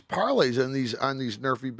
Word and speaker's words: NRFI: So how do you pronounce it? Everybody's been talking. It's called Nerfy.